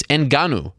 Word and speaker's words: Ngannou: It's Ngannou. Not that hard to pronounce.